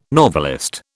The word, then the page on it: novelist: British English